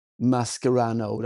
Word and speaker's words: Mascherano: Mascherano, den korrekta uttalet också.